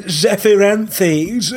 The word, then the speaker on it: Zephyranthes: Yes, I've been building, now forgive my pronunciation, the Gundam GP-01 Zephrantes? I don't know how to say it. – Zephyranthes. – It's a weird word. – It's actually Zephyranthes.